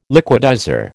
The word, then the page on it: liquidizer: American English